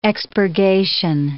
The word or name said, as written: expurgation